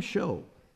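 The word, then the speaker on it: shew: Who is a wise man and endued with knowledge, who has knowledge among you, let him show. Now, I know there's some folks that call that shoot. I was taught growing up that the pronunciation of that is show.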